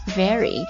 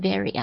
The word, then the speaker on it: vary: vary. 注意一下这个地方，这个 vary. 你看我读的时候，我有顿一下 very, very. 我没有读成 very 啊，读成 very 啊，就表示非常那个副词了。